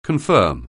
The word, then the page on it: confirm: anglais